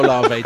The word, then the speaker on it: Olave: No, that's no, it's still Olave.